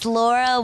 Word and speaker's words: Laura: Is that pronounced correctly? – Very close. It's Laura Warshaw. – I'll edit it in post. – Kind of like all about post. – Like war and shower.